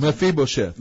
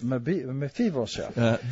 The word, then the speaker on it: Mephibosheth: Mephibosheth. – Mephibosheth.